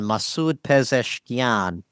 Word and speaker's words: Masoud Pezeshkian: Yes, we have a new president-elect in Iran, Masoud Pezeshkian, on the 5th of July. – Nice job on the pronunciation. I haven't mastered that one yet.